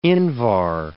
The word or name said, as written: invar